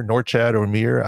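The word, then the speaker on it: Norchad Omier: Look, Norchad Omir, am I saying his name correctly? – Yep.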